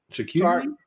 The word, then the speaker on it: charcuterie: Hey, babe, how you say that word? The charcuterie. – Charcuterie. – Yeah, I said it right. – Yeah, charcuterie. We were right.